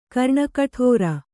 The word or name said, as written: ಕರ್ಣಕೋಟರ